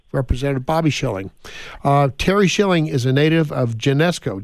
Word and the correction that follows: Geneseo: Did I say that right, Terry? – Geneseo. – Geneseo, okay. I didn't have the right inflection there.